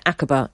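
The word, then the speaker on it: Aqaba: Akaba is the correct pronunciation.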